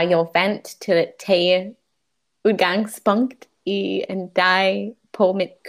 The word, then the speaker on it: udgangspunkt: Kun ordet udgangspunkt. I'll try to pronounce this soft D sound a little stronger.